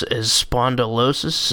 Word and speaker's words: spondylosis: What you're diagnosed with is spondylosis. Is that how what it's pronounced? – Yeah, yeah.